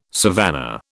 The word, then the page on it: savannah: British English